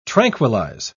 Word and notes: tranquillise: British English

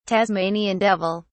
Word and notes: tasmanian devil: British English

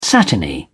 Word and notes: satiny: British English

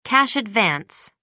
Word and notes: cash advance: American English

